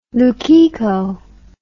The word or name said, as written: lukiko